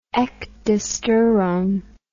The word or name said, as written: ecdysterone